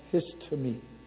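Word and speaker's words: histemi: So I looked up the word stand in the Greek, and it's pronounced histame, H-I-S-T-A-Y-M-E-E.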